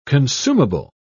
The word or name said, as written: consumable